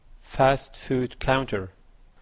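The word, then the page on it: fast food counter: Canadian English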